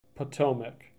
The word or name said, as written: Potomac